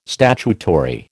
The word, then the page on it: statutory: American English